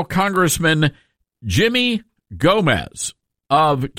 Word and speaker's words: Gomez: And no, the G is not silent, Dell. It's not Yomez. But it is silent on like Guatemala and guacamole. The G is not silent here.